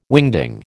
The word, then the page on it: wingding: American English